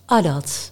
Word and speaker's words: adat: Ja, dat spreek je uit als ADAT.